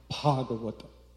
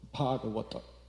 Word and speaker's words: Bhagavata: So they're all very careful of how they pronounce it. So they all say, Bhagavata, Bhagavata, Bhagavata.